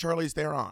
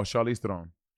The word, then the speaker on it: Charlize Theron: Well, you're not even pronouncing her name right. – I think it's Charlize Theron. – No, Charlize Theron.